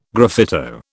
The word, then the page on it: graffito: British English